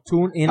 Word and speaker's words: TuneIn: se llama Tunnel, así que se pronuncia. – No, TuneIn Radio.